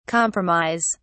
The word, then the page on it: compromise: British English